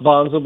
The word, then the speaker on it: Wanzl: It's pronounced Wanzl, but it's spelled Wanzl.